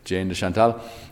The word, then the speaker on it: Jane de Chantal: They had this very interesting friendship, himself and, in English we call her Jane de Chantal. So, sorry, the pronunciation is not very French, but that is what we call her in English, Jane de Chantal.